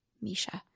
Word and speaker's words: Mesha: And my name is spelled M-E-S-H-A, Misha.